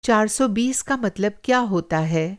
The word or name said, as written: चार-सौ-बीस